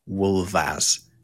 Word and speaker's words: wulfas: W-U-L-F-A-S, but an F in Old English, if it appears in the middle of a word and is between two vowel sounds, or L in this case, it makes a V sound. So wolf becomes wolvas.